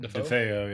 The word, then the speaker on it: DeFeo: 13 months before the Lutz family bought the house, the DeFeo, is that how you say it? – DeFeo, yeah.